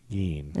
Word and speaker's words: Gein: Gein. That's how you say it.